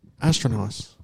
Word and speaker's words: Astronos: Say it right. – Astronos. – There you go.